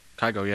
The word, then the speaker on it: Kygo: Kygo, yeah. – Is that how you say it? – Kygo, yeah.